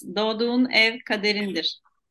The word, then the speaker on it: Doğduğun ev kaderindir: Doğduğun ev kaderindir.